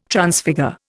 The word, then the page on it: Transfigure: Indian